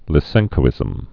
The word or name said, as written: Lysenkoism